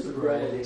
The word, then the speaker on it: sobriety: So when Ahasuerus regains soberty, that's how you pronounce it? – Sobriety. – Sobriety, okay.